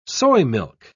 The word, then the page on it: soymilk: British English